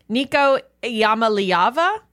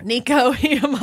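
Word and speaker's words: Nico Iamaleava: Nico Iamaliava. – Where's he from? – You know what? Iamaliava. Iamaliava. – He's from Middlesbrough. – Nico Iamaliava.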